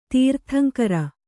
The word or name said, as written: ತೀರ್ಥಂಕರ